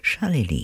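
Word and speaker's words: Charlélie: That was called Drifted, and it was from Charlie Lee, unusual name, C-H-A-R-L-E, accent on the E, L-I-E, Charlie Lee.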